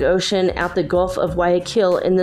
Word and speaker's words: Guayaquil: So if you're American, you can pronounce it in an English way. It'd be Guayaquil or Guayaquil, maybe. But it's in Spanish, it's Guayaquil.